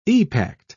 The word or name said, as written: epact